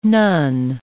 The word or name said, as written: none